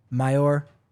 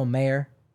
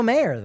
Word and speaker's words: Mayer: Why am I saying Mayor? Marcelo Mayer. I was criticized for my pronunciation for him recently, so now I'm second-guessing myself. Like the hot dog, Oscar. So maybe it's Mayer. Is it Mayer or Meyer? – Oscar Meyer, but it's...